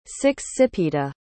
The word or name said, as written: sixsipita